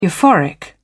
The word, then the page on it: euphoric: British English